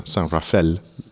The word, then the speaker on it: San Rafael: Um, y en la California no norte de San Francisco hay una ciudad, usan la pronunciación ah, francesa, San Rafael, dicen, ah, pero es el suburb norte de San Francisco, es nombrado por él.